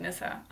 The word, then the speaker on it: Nissa: But there are these little Scandinavian elves called Nissa, or like little Nissa men, and it's spelled N-I-S-S-E. – But pronounced like Nissa. – Yeah, pronounced like Nissa. So that is what my first name means, according to my mom. – Okay. And can you spell your first name? – N-I-S-S-A.